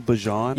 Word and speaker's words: Bijan: Did he just call him Bijan? – Yes, he did. – That's the correct pronunciation.